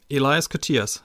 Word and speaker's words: Elias Koteas: Elias Koteas? – Elias Koteas, ja.